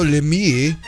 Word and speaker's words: Lemieux: On this day in 1991, which NHL franchise featuring Mario Lemieux won its first Stanley Cup? You have to pronounce it like that. Lemieux.